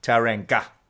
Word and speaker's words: Tauranga: Hey, you want to go up to Tauranga? Tauranga. Tauranga. Tauranga. There we go. Yeah, definitely the hard G. They would totally do the hard G. The ga. Tauranga.